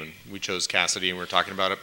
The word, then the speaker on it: Kasidie: But this is K-A-S-I-D-I-E. – Exactly, yeah. – Yes, and so, anyway, okay, so, but it's Cassidy, okay.